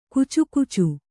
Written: ಕುಚುಕುಚು

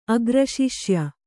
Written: ಅಗ್ರಶಿಷ್ಯ

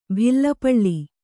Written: ಭಿಲ್ಲಪಳ್ಳಿ

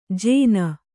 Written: ಜೇನ

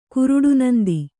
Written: ಕುರುಡುನಂದಿ

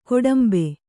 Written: ಗೋಡುಂಬ

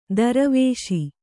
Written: ದುರ್ವೇಶ

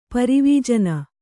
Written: ಪರಿವೀಜನ

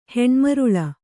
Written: ಹೆಣ್ಮರುಳ

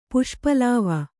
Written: ಪುಷ್ಪಲಾವ

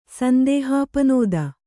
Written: ಸಂದೇಹಾಪನೋದ

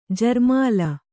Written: ಜರ್ಮಾಲ

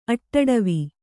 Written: ಅಟ್ಟಡವಿ

